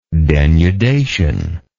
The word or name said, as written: denudative